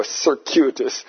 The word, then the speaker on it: circuitous: How do you say it? There you go, circuitous.